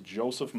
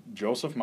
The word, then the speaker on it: Josef: My name is Joseph Maurer. My name is Joseph Maurer. Joseph is spelled J-O-S-E-F. Maurer is M-A-U-R-E-R.